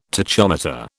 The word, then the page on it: tachometer: British English